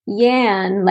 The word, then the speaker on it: Jan: So yes, Jan, like Jan rhymes with can.